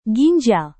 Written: ginjal

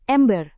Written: ember

